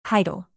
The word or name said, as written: title